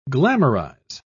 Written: glamorize

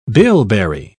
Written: bilberry

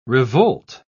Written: revolt

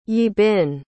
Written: yibin

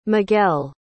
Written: miguel

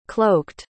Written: cloaked

